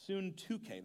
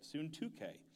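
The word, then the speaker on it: Syntyche: I plead with Euoda, and I plead with Suntuke, that's how you say it, Suntuke, to be of the same mind in the Lord.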